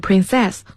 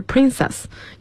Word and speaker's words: princess: 嗯， prince princess，那么是王子是 prince，那么我们公主可以读成 princess 或者是 princess，要注意王子和公主。